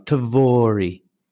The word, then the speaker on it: Tvori: How did you guys decide to come up with this kind of beautiful, almost rolls off your tongue, Tvori? – Yeah, I mean, and thanks actually for pronouncing it very well. That's not, people don't get the same success first time.